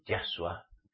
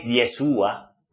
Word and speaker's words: Yahshua: Y hay que pronunciar el nombre de Yahshua. No es Yeshua, no.